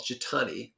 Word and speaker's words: Jitani: Well, I first got involved in Jedha New York, which Jedha NY, which then later came to be called Jitani, rhymes with Armani.